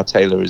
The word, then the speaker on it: Tayler: Yeah, yeah, and you know, Julian Shah Tayler is is my full name.